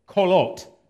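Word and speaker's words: qolot: Kolot. Say kolot. Kolot. Spelled with a Q, but it's pronounced kolot.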